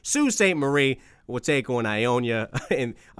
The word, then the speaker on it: Sault Ste. Marie: Am I saying this right? Say it for me, Matt. Sault Ste. Marie, is that what it is? – Sault Ste. Marie. – Sault, thank you for that. Why is it spelled S-A-U-L-T?